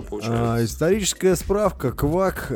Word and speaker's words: Kwak: Это пиво называется квак. Пишется как Гордость Кей... Бельгии, кстати. – Да. да. Оно пишется как K W A K, вот квак, такое так, так называется.